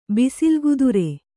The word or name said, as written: ಬಿಸಿಲುಗುದುರೆ